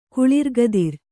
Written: ಕುಳಿರ್ಗದಿರ

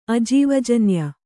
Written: ಅಜೀವಜನ್ಯ

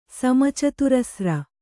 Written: ಸಮಚತುರಸ್ರ

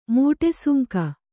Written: ಮಟ್ಟೆಸುಂಕ

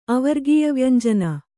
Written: ಅವರ್ಗೀಯವ್ಯಂಜನ

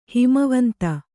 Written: ಹಿಮವಂತ

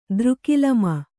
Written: ದ್ರುಕಿಲಮ

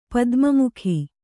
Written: ಪದ್ಮಮುಖಿ

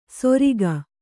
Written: ಸೊರಿಗ

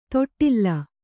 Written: ತೊಟ್ಟಿಲ್

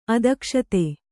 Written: ಅದಕ್ಷತೆ

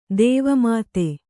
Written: ದೇವಮಾತೆ

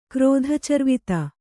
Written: ಕ್ರೋಧಚರ್ವಿತ

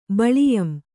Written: ಬಳಿಯಂ

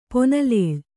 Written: ಪೊನಲೇಳ್

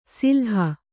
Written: ಸಿಲ್ಹ

